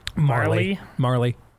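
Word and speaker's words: Majerle: Once lost in the finals of a free throw contest at a Michigan basketball camp to NBA player Dan Majorly's little brother. – Marley. – You have a J in it. Yeah, but that's how it's pronounced. The J is silent.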